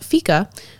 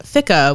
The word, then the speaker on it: fika: ask that same question is, in Britain, when they have tea time in the afternoon, in Sweden they have fika, which is the break you take with family or friends over coffee and some baked goods, usually.